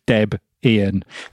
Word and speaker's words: Debian: He's the only person I know who puts the glottal stop in, but it's named after Deb and Ian, so it is Deb Ian.